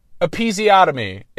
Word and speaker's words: episiotomy: Episiotomy. It's pronounced episiotomy, and I pronounced it episiotomy.